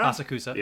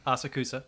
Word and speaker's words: Asakusa: Two of them, our main girl, Akatsuka? – Asakusa. – Yeah. – Asakusa. – Asakusa is how they pronounce it in Japanese.